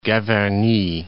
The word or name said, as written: Gavarnie